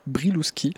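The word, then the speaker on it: Brylewski: Robert brilowski Briluski prononcé comme ça,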